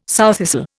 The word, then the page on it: sowthistle: Indian